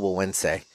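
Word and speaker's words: Güegüense: But I have since figured out that it is the El Buense. Even though it's spelled like G-U-E-G-U-E-N-S-E.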